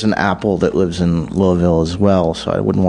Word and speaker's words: Louisville: He, Chad lives in Louisville, or Louisville, I'm supposed to say. Damn. – They want less syllables on that. – Louisville.